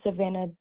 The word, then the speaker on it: Savannah: All right, my name is Savannah Doverspike. – How do you spell that? – S-A-V-A-N-N-A-H.